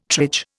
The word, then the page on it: triage: Indian